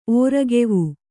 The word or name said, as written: ಓರೆಕಿವಿ